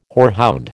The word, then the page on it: horehound: American English